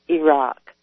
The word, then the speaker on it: Iraq: It is Pakistan, actually, and it is Iraq. – I know. – It makes me nuts to hear people mispronounce it.